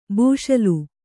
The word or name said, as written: ಬೂಷಲು